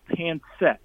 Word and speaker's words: pancit: and it's a dish called Pancit. It's P-A-N-C-I-T, Pancit, and it can be spelled with an S too.